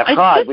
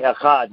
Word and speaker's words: Echad: Echad, we say Echad, oneness.